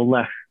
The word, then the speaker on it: Lech: Is it pronounced L-E-C-H? How's that one pronounced? – So it's, for some reason, C-H and H are almost the same in Polish. So you just pronounce that as if there was no C in there. So lek.